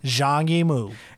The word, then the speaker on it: Zhang Yimou: And today, we're talking about famed mainland Chinese director Zhang Yimou.